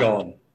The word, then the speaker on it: Sean: Is it Sean? – Sean. Yeah, okay. It's an Irish name, actually. I know in America they spell it S-H-A-U-N or S-H-A-W-N, but no, it's actually an Irish name. I'm Irish myself, and the little accent is Irish. So, yeah, but it's pronounced exactly the same.